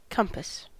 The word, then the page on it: compass: American English